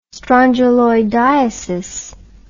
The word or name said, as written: strongyloidiasis